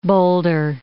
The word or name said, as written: Boulder Dam